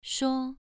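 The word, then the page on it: shuo: tone 2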